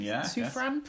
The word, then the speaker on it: Sufjan: Is that how you say his name? Sufran. – Yes.